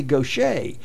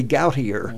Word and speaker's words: Gautier: And, of course, they say Gaucher. They don't say Gautier, Mississippi.